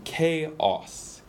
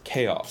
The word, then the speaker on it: chaos: 근데 이 말이 나온 김에 so 영어로 얘기할 때 맞는 발음은 chaos, chaos. – Chaos. – So 이 명사형은 chaos. So 한국어로 뭐예요? 카오? – 그 혼돈 그러는데 흔히 발음할 때 chaos 이렇게 발음을 많이 해요. – Chaos. Chaos. 그렇게 chaos. 얘기하면 사람들이 소들이라고 생각할 거예요. So, chaos? – Chaos.